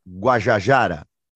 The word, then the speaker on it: Guajajara: Me corrija a pronúncia, Sônia Boni Guajajara.